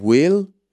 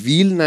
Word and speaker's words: will: به تلفظش هم دقت کنید دیگه یا باید بگیم will will نگیدا will اصلا و نمیشه و یعنی لبا گرد میشه صدای W و اوکی وی نیست صدای V یه چیز